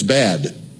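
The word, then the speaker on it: bad (בד): The word for alone in Hebrew, if you say it in English pronunciation, it's bad. It's just B-A-D.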